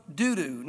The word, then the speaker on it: Dudu: After him was Eleazar, the son of Dudu. Now, I'm not trying to be funny. I know that sounds bad. Dudu's how you pronounce it.